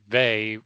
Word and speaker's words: Veighey: Vey, V-E-I-G-H-E-Y. It's spelled longer than it sounds.